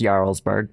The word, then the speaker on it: Jarlsberg: Pronounced Jarlsberg mm-hmm. for all of you cheese noobs.